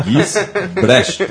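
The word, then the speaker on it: Gisbrecht: O Ale já corrigiu a gente aqui, é Gis Brecht. – Ah, então Gis. Entonação, né? Sílaba tônica, Brecht.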